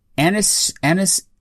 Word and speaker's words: anisotropic: Which enables titles to render with increased resolutions up to 4K, or applying anisotropic filtering. Did I say that right, Manny? – Yes, you did.